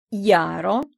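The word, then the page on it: jaro: Esperanto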